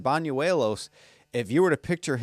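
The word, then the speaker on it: Bañuelos: Banuelos. I got that pronunciation, by the way, from the Yankees PR people. I thought it was Banuelos too, but it's Banuelos.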